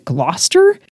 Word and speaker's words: Gloucester: And there was something that rhymed with Gloucester, which if you've ever seen that word written down, it does not look like you would pronounce it Gloucester. Anyway, you would know the pronunciation of the word Gloucester if you had seen the line before it, because it was Foster or something.